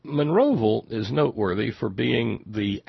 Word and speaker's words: Monroeville: This one out of the little hamlet of Monroeville, and that's how you say it, Monroeville. It's not Monroeville or Monroeville. It's Monroeville. Monroeville, Alabama.